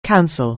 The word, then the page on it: counsel: anglais